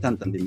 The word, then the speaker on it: タンタン: えっと、タンタン、えー、ライツホルダーズ、これまあ英語的にはティンティンって発音しますけど、えっと、タンタン、フランス語風にタンタンで言いますね。タンタン、